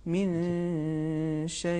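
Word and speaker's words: من شيء: صحیح تلفظ یہ ہے من شے یعنی جو نون ساکن ہے اخفا کی صورت میں اسے کھینچا جاتا ہے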